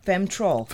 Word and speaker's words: Femtrol: That is A-G-N-U-S. Uh-huh. And then cactus, okay. C-A-C-T-U-S. – Okay. – And a remedy called Femtol. – Femtrol. – Femtrol. – F-E-M-T-R-O-L.